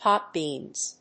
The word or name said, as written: pop beans